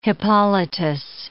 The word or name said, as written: Hippolytus